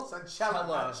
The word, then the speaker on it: Sunchella: Sunchella. – Cello. – It's like Coachella.